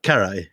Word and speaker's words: Carrhae: Am I pronouncing it right? Carrhae? – Carrhae.